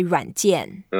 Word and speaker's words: 软件: We say 软件.